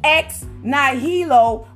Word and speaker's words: ex nihilo: That's E-X-N-I-H-I-L-O. I'm gonna spell it again. It's pronounced ex nihilo. It's a Latin word.